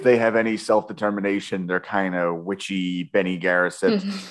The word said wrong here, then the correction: Bene Gesserit: which, by the way, I am going to be mispronouncing everything. And it's okay. You guys can just kind of deal with that. – Yes. Yeah, no, the Bene Gesserit, which, sorry to correct you, the Bene Gesserit.